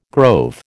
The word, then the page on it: grove: American English